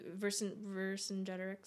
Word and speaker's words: Vercingetorix: I mean, the Vercingetorix, is that right? – Yeah, you're saying it right. Okay. Don't worry about it. You got it.